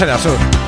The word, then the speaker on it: Erasure: Tantas formas, tantas maneras de pronunciarlo mal, Los Erasure, para nosotros Erasure.